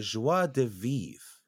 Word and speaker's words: joie de vivre: We do enjoy to appreciate, how do you say, the joie de vivre, Koresk.